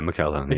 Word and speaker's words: McElhone: let me make sure I pronounce, is it Natasha McElhone? – I would say, McElhone, yeah.